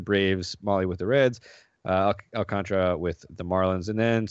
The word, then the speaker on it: Alcantara: We'll start off with Sandy Alcantara, or Alcantara, I suppose, as it's supposed to be pronounced, down at 100.